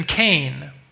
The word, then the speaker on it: Cain: In Genesis 4:1, she is so excited to have made a man with the Lord's help that she names him Cain, which rhymes with gain.